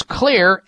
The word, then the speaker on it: Xlear: So if you have nasal allergies, use Clear, X-L-E-A-R, that's how you spell it, Max nasal spray.